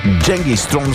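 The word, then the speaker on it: Genghis Tron: Дженгис Стронг всъщност е истинското им произнасяне, произношение.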